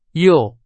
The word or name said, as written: 右